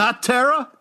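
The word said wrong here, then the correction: Nocterra: Don't pronounce it weird. – Noctera. – Thank you. – Noctera.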